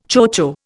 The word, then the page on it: chocho: Indian